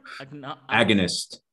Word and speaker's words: agonist: And it acts as a non-selective agonist. Saying this right? – Agonist.